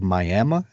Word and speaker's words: Miami: So you talked about growing up in Oklahoma, in a little town called Miami.